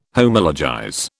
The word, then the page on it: Homologize: British English